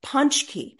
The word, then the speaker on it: pączki: Now, that makes no sense, but what it is in Poland, it's a big celebration, and they have these donuts called pączki.